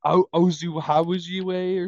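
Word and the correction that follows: Okwuegbunam: or something? – Not even close. – I thought that was pretty close. – Thank you. – No, it wasn't. – It's like Ogwegbenam. Ogwegbenam. – That sounds awfully close to what I just said. – Your pronunciation had 18 L's in it. – Yeah, you had like 40 vowels in that shit.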